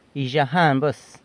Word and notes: Yzhajan buas: San Lucas Quiaviní

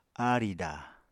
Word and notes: aarìda: Apatani

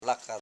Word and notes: laka: la.ka

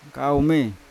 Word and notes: kaume ko: Yawa

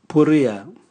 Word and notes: buria: Raga

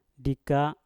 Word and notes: dika: Mpur

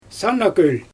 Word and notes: sana kölJ: Xyzyl

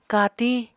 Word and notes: katiñ: Apatani

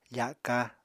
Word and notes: faka: Apatani

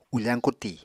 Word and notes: ufañ kuti: Apatani

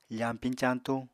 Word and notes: fañpiñ-cañtuñ: apatani

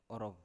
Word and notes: orov: Biak

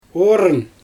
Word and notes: ooryn: Xyzyl

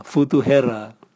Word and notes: vutuhera: Raga

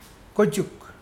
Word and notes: kojukdi: Mokilese

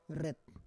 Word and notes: ret: Roon

